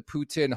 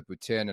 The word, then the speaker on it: poutine: It's nowhere close to the poutine. And I say it properly. It's poutine, not poutine.